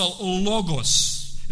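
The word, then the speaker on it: логос (λόγος): Мы когда говорили на первый стих, мы обратили внимание, что на греческом языке оно произносится «логос».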